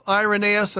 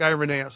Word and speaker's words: Irenaeus: Yeah, well, to be honest, it's got both Irenaeus and Irenaeus. I've heard it both from the academic world. And so I thought, well, if they're not uniform on the pronunciation, I like Irenaeus, so I'm going to run with it.